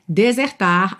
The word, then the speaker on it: desert: D-E-S-E-R-T. D-E-S-E-R-T. Mas a sílaba tônica é a primeira e esse é aberto. Então a gente fala desert. Desert.